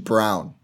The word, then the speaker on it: Braun: So EJ Liddell, Walker Kessler, Christian Braun, Kennedy Chandler, Tari Eason, I think. And actually, sorry, it's Christian Brown, not Braun. It's spelled Braun, pronounced Brown.